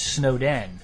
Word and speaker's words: Snowden: It's Snowden, S-N-O-W-D-E-N, not Snowden, as in Snowden because of a blizzard.